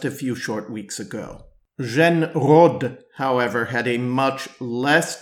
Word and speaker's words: Jeanne Rhodes: One exception is the Falcon, I would call her, Jane Rhodes, as Americans would pronounce it, Jeanne Rhodes, as the French would say,